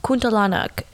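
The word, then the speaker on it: Kuntilanak: I actually asked my coworker, who is Indonesian, which is where the word originates from, and it's actually pronounced Catalanic.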